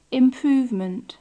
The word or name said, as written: improvement